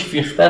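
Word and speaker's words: Fichte: خب اولی فیلسوفی که بعد از کان مطرح، یعنی در دو نفر مطرح، نمیدونم بدونیدن خیلی نمیخوام بحث کنم، یکی فیشته یا فیخته، ظاهرا تلفظ آلمانیش فیخته است اما بس اش میمیسن، وقت انگلیسی فیشته رو طرف میخونن، یکی فیخته رو داریم، یکی شیلینگ.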